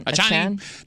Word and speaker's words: Achane: Running back Devon Achane. Is that how you say it? – Achane.